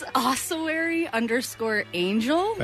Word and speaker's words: ossuary_angel: Yeah, my modeling and everything else where you can see the raw meat picture, that's, it's ossuary_angel.